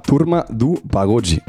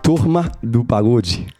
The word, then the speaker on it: Turma do Pagode: Turma do Pagode. – Sí, está bien.